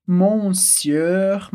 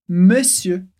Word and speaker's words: monsieur: De novo, lembrando que na palavra senhor a gente escreve monsieur, mas a gente não pronuncia como se lê, a gente fala monsieur. Ela repete comigo de novo. Monsieur. Sim, exatamente. São duas vezes o sonzinho E.